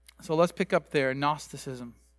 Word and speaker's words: Gnosticism: And it's, in English, we don't pronounce the G. We do that with a lot of Greek words. But in Greek, it's Gnosticism, if you can say it real fast.